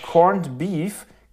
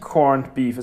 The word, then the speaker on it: Corned Beef: Es, heißt ja nicht, es heißt ja nicht, es heißt nicht Corned Beef, es heißt Corned Beef. Aber warum? Weil die alten Leute nicht äh, der englischen Aussprache damals nach dem Krieg mächtig waren, so hundertprozentig, und Dinge einfach so genannt haben, wie sie genannt werden.